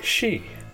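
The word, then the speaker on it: Xi: And did you know that XEI in Chinese is pronounced more like Shee?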